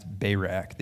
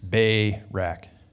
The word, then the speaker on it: Barak: And by the way, I think it's properly pronounced Barak. The emphasis is on the first syllable, right? It's Barak. I think that's how you would say that in Hebrew.